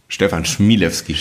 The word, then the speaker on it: Stefan Chmielewski: Mein heutiger Gast ist Stefan Schimilewski. Ähm, falsch ausgesprochen? Sag es bitte einmal richtig. Stefan Schmielewski.